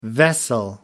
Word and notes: vessel: American English

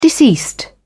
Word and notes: deceased: British English

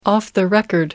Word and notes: off the record: British English

